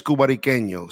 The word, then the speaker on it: Cubariqueño: but the actual name of the company is Cubariqueño.